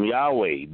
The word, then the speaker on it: Yahweh: And then, hallelujah, it is pronounced and vocalized, Yahweh. I am Yahweh.